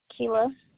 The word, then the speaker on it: Kelah: Kayla. – Can you spell that for me? – K-E-L-A-H.